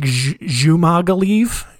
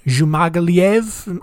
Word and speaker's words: Dzhumagaliev: His last name is spelled D-Z-H-U-M-A-G-A-L-I-E-V. So I don't know what sound D-Z-H means. I think it's like Zumagaliev, Zumagaliev. I think I'm just going to say Zumagaliev. I'm just going to assume that that D is silent, and we're going to move on.